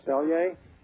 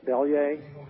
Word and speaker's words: Bellier: Hello, this is Jeff Bellier. Welcome to the Mind Goal Hour. Again, my name is Jeff Bellier. That's Bellier. You say it like ringing a bell, Bellier.